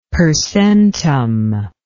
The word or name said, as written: per centum